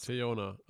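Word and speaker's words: Teyonah: Ähm, heißt sie Theona Paris oder Theona Paris? – Theona, aber okay.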